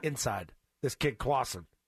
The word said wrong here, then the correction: Crossan: Okay, Crossan or Clausen? How do you say his Crossan. – Name? – Crossan. – Crossan.